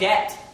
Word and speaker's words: debt: When you read the word S-U-B-T-L-E in the Bible, that B is silent. It's just like debt, the word debt, like you say I'm in debt, you don't say I'm in debt, debt.